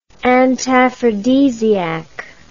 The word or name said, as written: antaphrodisiac